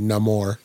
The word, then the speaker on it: Namor: And, Namor, as he's pronounced in the movie, Namor.